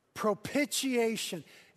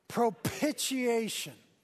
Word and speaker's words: propitiation: Propitiation. It helps if you spit a little bit on the second syllable. Ready? Here we go. Propitiation.